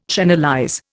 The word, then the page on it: channelize: Indian